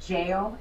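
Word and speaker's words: gaol: She runs it's spelled Goal, but they say it Jail.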